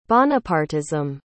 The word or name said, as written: Bonapartism